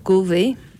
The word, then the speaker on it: Gouvy: Our next piece this evening is by Louis Théodore Gouvet. His last name is spelled G-O-U-V-Y.